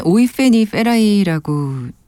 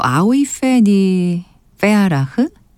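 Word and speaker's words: Aoife Ní Fhearraigh: 아, 참 이름은 이게 고유 명사라서 사실 이게 본인이 어떻게 불러달라고 하느냐에 따라서 이 발음이 꽤 달라지는 경우가 많잖아요. 우리에겐 오이 페니 페라이라고 좀 꽤 알려진 가수인데 또 아오이 페니 페아라흐라고 부르기도 한다는데 그녀한테 직접 물어볼 수도 없고 친숙한 발음대로 하겠습니다.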